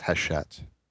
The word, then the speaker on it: Hachette: For all of our French listeners, we know it's pronounced Hachette.